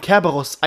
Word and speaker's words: Kerberos: Ah, okay, ich kenne als Cerberus mit, mit C an der Aussprache. Ja, Kerberos wie Chemie oder China.